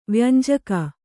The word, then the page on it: ವ್ಯಂಜಕ: vyanjaka